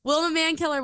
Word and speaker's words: Wilma Mankiller: I want to make sure that I say it correctly because I think I said it wrong. – I've never even heard that name. So please. – She's a badass. Wilma Mankiller was. Oh my gosh, I was right.